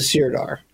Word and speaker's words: Sirdar: He gets appointed Sirdar. Am I saying that correctly? – Yeah, he's the Sirdar.